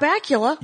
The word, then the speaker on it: Bakula: Which Scott Bakula, yeah. – Which rhymes with butt crackula.